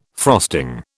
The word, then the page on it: frosting: British English